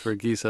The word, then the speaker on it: Verghese: Did I pronounce that correctly? – Yes, it's Verghese as in geese.